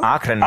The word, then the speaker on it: Akren: Akren. – Ah, wird, schon mal, wird schon mal anders. Das wollte ich sagen, wird anders ausgesprochen. Akren wird es natürlich ausgesprochen.